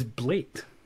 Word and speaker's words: blate: The Scottish word of the week this week is blate. – Blate. – Blate. – Spell it for us. – B-L-A-T-E.